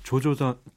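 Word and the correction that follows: Joe Jonas: 조 조나스.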